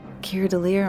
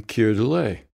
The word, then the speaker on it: Keir Dullea: There were other actors in there too, like Keir Dillier, the star of 2001, Space Odyssey. Keir Dullea. Am I saying the name right? – I am Keir Dullea.